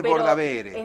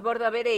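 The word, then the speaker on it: Bordabehere: Se escribe Bordavere, no creo que sea GR. Es Bordavere. – Es Bordavere y le decían Bordavere.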